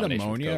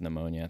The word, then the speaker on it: pneumonia: And that is it ammonia or pneumonia? – I think it's pneumonia. I think you're saying that right. – Pneumonia. – Yeah, it's PN and the P is silent.